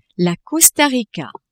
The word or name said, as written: Costa Rica